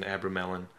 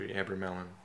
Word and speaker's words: Abramelin: No. Abermelin. – Oh, okay. – Yeah, like Abra. – Melon. – Yeah, Abracamelon. It's a story of an Egyptian mage whose name Abraham, his pronunciation Abramelin. – You mean Abraham? – No, is named Abraham. The proper pronunciation is after Abramelin.